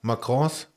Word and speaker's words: Macarons: Spricht man die so Macron. aus? – Macarons? – Macarons. Macron ist ihr Präsident, ich weiß es nicht.